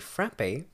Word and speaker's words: frappe: In the Boston area, a frappe, pronounced frap and spelled with the accent, or really? It's just pronounced frap, I guess that makes sense, is a thick milkshake with ice cream derived from the French word frappe.